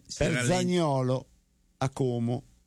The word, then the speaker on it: Como: L'hai detto bene, perché lo dicono con la O chiusa. A Como.